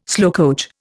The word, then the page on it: slowcoach: Indian